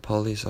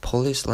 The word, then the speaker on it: police: But so like usually most Korean they pronounce police like just like me, like they pronounce like police, and they put accent on P, so it's gonna be like police.